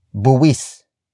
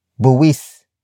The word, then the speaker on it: buwis: And that happens to be the main noun of the sentence, which is buwis. Buwis. Buwis. Buwis is spelled as B-U-W-I-S.